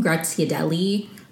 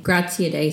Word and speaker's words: Graziadei: I have to say, I called him Graziadelli or something in one of my first episodes. I'm sorry that I mispronounced his name. Graziadei is still a cool Italian name.